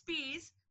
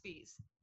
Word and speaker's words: Spees: So my it's Spees, looks like spies, SPIES. Okay. Everybody says spies. I've had to show my ID to prove, but it's pronounced Spees.